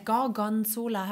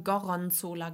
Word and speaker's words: Gorgonzola: ähm, äh, Gorgonzola habe ich immer falsch gesagt. Ich habe Goronzola gesagt.